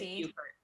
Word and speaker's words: Beaufort: Is it B-E-A-U-F-O-R-T. It's Buford.